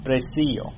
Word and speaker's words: precio: Que el pecado está allí, de que Jesús no ha pagado ya el precio. Precio. No accent on the eye.